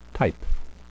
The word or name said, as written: type